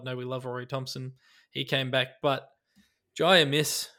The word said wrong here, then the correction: Amos: sorry, that's not how you pronounce his name. – Amos.